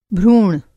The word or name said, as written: भ्रूण